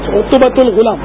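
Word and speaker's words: عتبہ الغلام: حضرت سیدنا عتبت الغلام غالباً عتبت الغلام, ہاں مولانا عتبت العتبت ال کیا صحیح تلفظ کیا ہے, عتبت الغلام جزاک اللہ,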